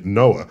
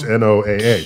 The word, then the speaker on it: NOAA: Get NOAA to do that, the National Oceanographic and Atmospheric Administration, which has the clever pronunciation, NOAA, because it monitors like floods and things like that. – Ooh, that's how you pronounce NOAA.